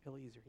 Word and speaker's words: Eliezer: And yet, Eliezer still, did I say that right? Eliezer, yes.